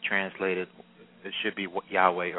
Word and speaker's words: Yahweh: which means the most popular pronunciation is Yahweh, although some people say is Yehovah, and they can't make up their minds what it is. So Yahweh is really the most popular pronunciation of God's Hebrew name.